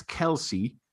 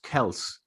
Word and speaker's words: Kelce: he gets Kelce, but it's actually pronounced Kels.